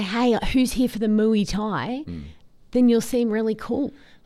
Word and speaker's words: Muay Thai: Say it properly. – What do you call it? – It's Muay Thai.